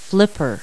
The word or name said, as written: flipper